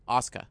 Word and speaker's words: Asuka: And you talked about the pronunciation of this name, Asuka. – Yeah, you got it.